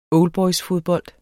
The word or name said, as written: oldboysfodbold